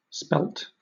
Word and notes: spelt: British English

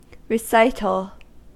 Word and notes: recital: American English

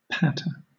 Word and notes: patter: British English

